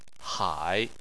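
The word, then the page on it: hai3: tone 3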